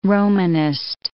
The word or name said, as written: Romanist